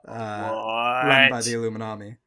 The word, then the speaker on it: Illuminati: Now, I'm pretty sure you mispronounced yours. It's Illuminati. It's the Illuminati.